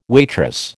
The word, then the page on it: waitress: American English